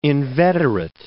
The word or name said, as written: inveterate